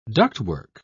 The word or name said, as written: ductwork